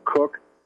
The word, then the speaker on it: Cook: Cook, and make sure you spell it correctly.